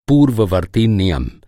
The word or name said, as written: पूर्ववर्ती